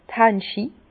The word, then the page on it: d' Hanschi: Bas-Rhin; Herrlisheim